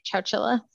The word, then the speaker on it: Chowchilla: Chowchilla? – I think that's it.